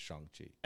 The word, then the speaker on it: Shang-Chi: Those words pronounced with a Asian accent is Shang. – Yes. – And so that's what they've decided to go with is Shang-Chi.